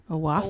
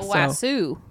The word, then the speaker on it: Owasso: O-W-A-S-S-O. – Owosso. – Owosso.